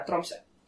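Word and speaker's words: Tromsø: Ударение все таки да, на первый слог. То есть, как бы, да, Тромся.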